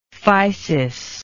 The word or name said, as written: physis